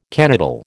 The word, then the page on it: canoodle: American English